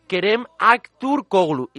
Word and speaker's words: Kerem Aktürkoğlu: con el rumor de Kerem Aktürkoğlu, y lo he dicho bien, aunque no es que sea especialmente habitual hablar en turco.